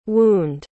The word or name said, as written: wound